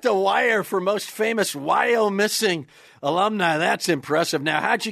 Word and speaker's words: Wyomissing: It is Wyomissing.